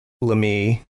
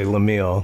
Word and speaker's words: Laemmle: Young Hollywood producer Carl Laemmle. I was gonna, like I said, I want to say Lemille because the way it's spelled.